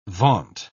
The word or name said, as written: vaunt